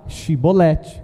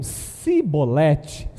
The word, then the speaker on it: chibolete: Agora, eles só que eram um povo que eles não conseguiam falar chibolete. Eu acho que tinha língua presa, sabe? Se eles diziam cibolete, cibolete, não podendo pronunciar corretamente a palavra, eles o agarravam e matavam ali mesmo, na valsa do, do Jordão.